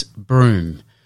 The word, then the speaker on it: brume: And this week's Word of the Week is broom, and that is spelt B-R-U-M-E.